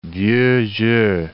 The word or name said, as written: vieux jeu